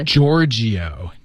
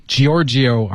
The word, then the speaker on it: Giorgio: Giorgio. Giorgio Armani. – Giorgio. – Yeah, G-I-O-G-I-O. G-I-O-R-G-I-O.